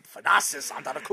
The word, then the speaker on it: Thanasis Antetokounmpo: Wow, I'm very impressed how you pronounce that name. Thanasis Antetokounmpo.